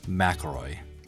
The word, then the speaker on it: McElroy: And today's guest is Luke McElroy.